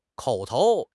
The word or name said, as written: 口头